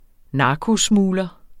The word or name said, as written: narkosmugler